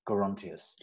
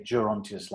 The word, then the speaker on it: Gerontius: Elgar said it should have a hard G, by the way. Gerontius. – Gerontius. – I always wanted to say Gerontius like Gerald, but...